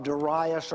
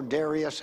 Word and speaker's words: Darius: And that month, we learned earlier in the chapter, is the 9th month of the second year of Darius, or Darius, however you prefer to pronounce it, in the second year of his reign.